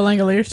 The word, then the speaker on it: Langoliers: The Langoliers.